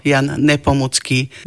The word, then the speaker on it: Jan Nepomucký: Povedala som, že je český svetec a mučeník, preto Jan Nepomucký vyslovujeme tvrdo.